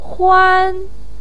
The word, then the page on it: huan: 1